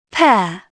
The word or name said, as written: pair